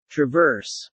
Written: traverse

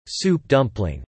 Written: Soup dumpling

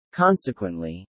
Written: consequently

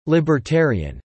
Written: libertarian

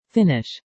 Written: Finnish

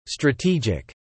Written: strategic

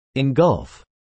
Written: engulf

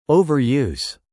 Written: overuse

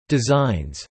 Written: designs

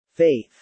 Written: faith

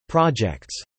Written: projects

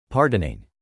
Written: pardoning

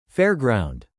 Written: fairground

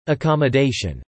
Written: accommodation

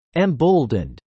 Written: emboldened

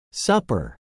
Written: supper

